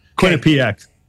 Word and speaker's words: Quinnipiac: Quinnipiac.